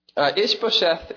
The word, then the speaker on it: Ishbosheth: I always mispronounce this guy's name. I feel really sad for Mephibosheth. No, that's a different word. Ishbosheth is made king. Yes, thank you for pronouncing that correctly.